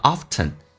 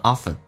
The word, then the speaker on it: often: 中是发两次的，第一个 man，第二个是你 many， many people，记住这是一个长元音 e，嘴角往后咧一点，通常 p 跟 l 趋向于发 b 跟 l 叫 people，如果速度快一点， Many people often think of, often, 有可能有的人说成 often, 有人说成 often, 都是对的。